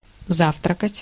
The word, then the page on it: завтракать: /ˈzaftrəkətʲ/